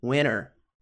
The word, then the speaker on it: winter: Não pronunciam esse T, tá? Então, ao invés de winter, americanos muitas vezes falam winter, ok?